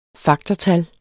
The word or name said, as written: faktortal